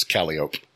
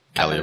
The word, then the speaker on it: Calliope: In New Orleans, you have a street that's spelled Calliope, but it's Calliope. – Calliope. Oh, yeah. – Yeah. – Yeah. You say Calliope, they look at you like you're stupid.